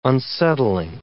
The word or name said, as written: unsettling